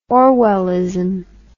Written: orwellism

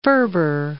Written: Ferber

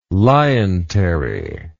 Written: lientery

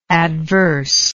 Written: adverseness

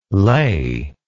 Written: lai

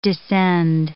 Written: descend

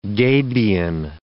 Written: gabion

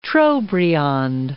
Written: Trobriand

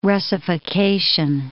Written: Russification

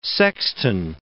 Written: sexton

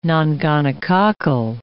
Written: nongonococcal

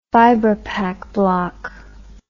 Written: vibropac block

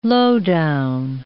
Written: low-down

